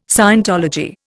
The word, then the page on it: scientology: Indian